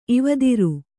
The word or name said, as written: ಇವದಿರು